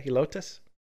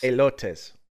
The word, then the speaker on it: Helotes: As drivers unfamiliar with the area, that might sound extreme, but at Helotes, is that how you say it? Helotes? – Elotes. – Elotes. – Yeah, Elotes.